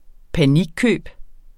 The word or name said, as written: panikkøb